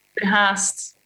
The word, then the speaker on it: Verhaest: Verhaest.